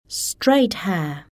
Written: straight hair

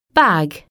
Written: bag

